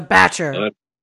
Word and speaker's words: bach: It's where like Kiwis like to retreat to on their holidays, and we just call them batches. – It's a batcher. – Batch. Yeah, it's spelled B-A-C-H, like that composer. – Oh, Bach. Okay. Like Bach.